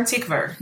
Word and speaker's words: Tykwer: Tykwer, he's German. – Tykwer, okay, thank you.